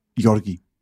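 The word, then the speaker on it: György: Ja, vil du prøve den rigtige først? – Georgi. Altså, det er jo Ungarn, jo, ikke? – Sådan. – Georg. Ikke? Han hedder George.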